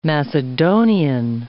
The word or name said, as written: Macedonian